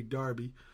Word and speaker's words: Derby: We had Sophie Sparham and Christopher Gregory out of, now it's spelled, we'd spell it Derby, huh? But they say Darby.